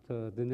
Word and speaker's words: Dineh: Sometimes Dene is spelled D-I-N-E with a slash on top of the E, but she spells hers D-I-N-E-H because people see Dene spelled D-I-N-E. They think it's time. It's time to eat.